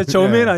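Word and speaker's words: Jermaine: 뭐 절만 스튜어트라고 읽나요? 맞나요? 야, 저메인 네. 아니야 저메인? 저메인인가요? 아, 절만이 아니에요.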